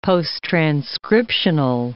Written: posttranscriptional

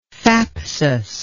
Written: thapsus

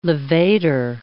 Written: levator

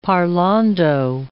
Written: parlando

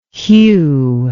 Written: Hugh

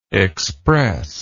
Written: expressless

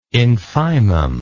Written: infimum

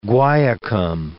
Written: guaiacum

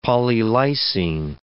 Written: polylysine

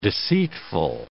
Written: deceitful

